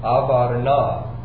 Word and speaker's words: Avarnah: This is actually the threshing place of Avarnah, the Jebusite. All right? To spell it, you know, in English, we would spell it A-V-A-R-N-A-H. Avarnah, the Jebusite.